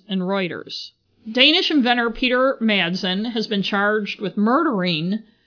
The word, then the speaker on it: Madsen: I think I kept saying Mazden instead of Madsen. For some reason, it's hard to say Madsen.